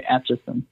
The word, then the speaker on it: Atchison: You did a 3-part episode of a very active location, the McIntyre Villa, in what is it, Atchison? I believe I'm saying that wrong. – No, you're right, Atchison.